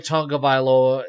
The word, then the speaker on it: Tagovailoa: Tua Tagavaloa. Did I pronounce that right? – Yeah, well, Mike McDaniels, you know, runs a, you know, just a, a track meet as an offense. And – Tua Tagovailoa is the perfect quarterback for his system.